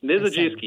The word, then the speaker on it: Mizejewski: Thank you both, Peter Gross and David Mizejewski, I'm going to say it wrong, right? – Mizejewski, you almost got it there.